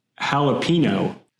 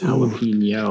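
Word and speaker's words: jalapeño: She says jalapeno. – Yeah, exactly. – Okay. – Yes. – And I said, Stephanie, do you see that little tilde over the end? You're saying it wrong. It's jalapeno.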